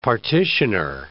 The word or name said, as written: partitioner